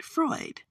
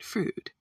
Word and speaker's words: Freud: Now, for those not from here, I'm saying food, but we're anywhere else were anyone else to talk about that street. They'd call it Freud. But in Detroit, we pronounce it Frood.